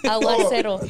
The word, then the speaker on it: Aguacero: Am I saying the name one right? Aguacero? Yeah, right? – Aguacero. Okay.